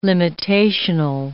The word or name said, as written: limitational